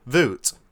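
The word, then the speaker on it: Wout: How do you say his first name? I still have no idea. Voot.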